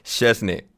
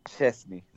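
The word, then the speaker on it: Szczęsny: porque nunca me salió. ¿Cómo es? – Chesney. – Chesney. – Sí, muy bien.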